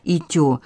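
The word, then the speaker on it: «итё» (イチョウ): В Японии название дерева гинка произносится как «итё».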